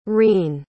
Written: reen

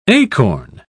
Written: acorn

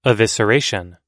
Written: evisceration

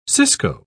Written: cisco

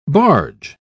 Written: barge

